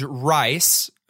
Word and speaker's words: Rhys: But it begins with your character named Rice. R-H-Y-S.